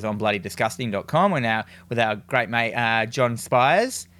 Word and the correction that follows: Squires: Is that how you say his name? Squires.